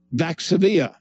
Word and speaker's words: Vaxzevria: If you're talking about the AstraZeneca shot, right, it will be called Vaxzevria, right? That's spelled V-A-X-Z-E-V-R-I-A, Vaxzevria, okay?